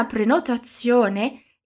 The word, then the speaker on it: prenotazione: Aqui eu quero que você preste um pouquinho mais de atenção na pronúncia do Z em italiano. É igual quando a gente liga no delivery para pedir uma bella pizza. A pronúncia do Z na palavra prenotazione vai ser igualzinho a esse Z que falamos na palavra pizza ou pizzaria.